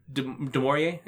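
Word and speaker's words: Du Maurier: And Dr. Demorier, am I saying it right? Yeah.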